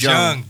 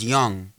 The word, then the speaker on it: Jung: I'm pretty sure the J is silent. – Yeah, I think it's soft. Everybody outside that just reads it says George Jung, but I think he probably pronounced it as Young.